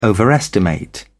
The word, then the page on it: overestimate: British English